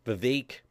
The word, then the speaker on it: Vivek: But there's even, they're playing out that Vivek rhymes with Cape, Van Swam Ron Swami might, might actually, that could become the ticket of him and President Trump.